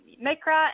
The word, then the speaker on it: Mikra: The book is The Eyes of Mikra. Is that how you say it? Mikra? – Yes. That's Mikra and MIKRA.